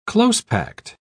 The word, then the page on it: close-packed: British English